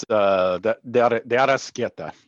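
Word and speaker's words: De Arrascaeta: 5 minutes later, someone help me with the pronunciation. De Arasqueta? – It's De Arasqueta. Yep.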